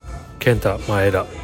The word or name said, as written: Kenta Maeda